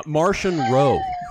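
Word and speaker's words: Marchion Ro: Uh, Martian Ro. – oh, like Martian the Manhunter.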